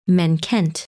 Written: Menkent

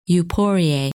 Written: Euporie

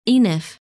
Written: Enif

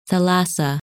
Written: Thalassa

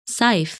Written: Saif

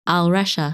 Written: Alrescha

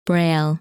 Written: Braille

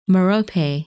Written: Merope

